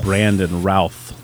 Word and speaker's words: Brandon Routh: And then Brendan Routh. Brandon Routh. Thank you. I butchered that name.